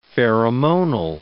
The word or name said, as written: pheromonal